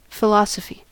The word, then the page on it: philosophy: American English